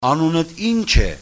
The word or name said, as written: anuninch